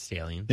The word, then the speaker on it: Stalions: That's how I say his name, right? Stalions. I think it's Stalions. – Yeah, there's just one L in there.